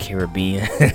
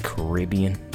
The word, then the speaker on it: Caribbean: A lot of people call it Caribbean. But it's really actually pronounced Caribbean.